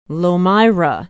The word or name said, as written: lomira